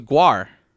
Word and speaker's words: Gwar: No, it's Gwar. They've always pronounced it Gwar. – I'm gonna pronounce it Gwar. – Gwar. – Because it's like war with the gut.